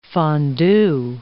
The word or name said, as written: fondue